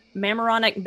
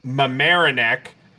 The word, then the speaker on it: Mamaroneck: Roger Paul Craig, excuse me, who lived in Mamaroneck Bay, New York, during the time. – That's Mamaroneck.